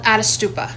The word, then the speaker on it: Ättestupa: But it's uh, uh, I believe it's it's it's Puya. Is that how you say it? – It's called Ättestupa. – Ättestupa.